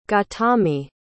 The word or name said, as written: gautami